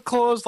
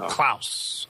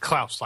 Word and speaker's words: Claus: Is it pronounced Claus like Santa? – It can be, but usually it's Klaus. So. – Klaus. Okay. – Yes. – All right. Klaus, like the fish in American Dad.